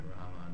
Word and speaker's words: Rama: But Rama, the A is very short, so Hare Krishna.